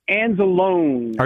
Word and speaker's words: Anzalone: His name is pronounced Anzalone.